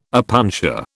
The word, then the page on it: opuntia: British English